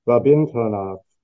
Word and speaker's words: Rabindranath: Rabindranath, I think so. I think the accent's on the second syllable there.